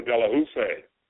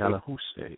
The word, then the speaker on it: Delahoussaye: Sadly, I have to announce that Dr. Delahoussaye, that's how you pronounce it. – Delahoussaye, yes.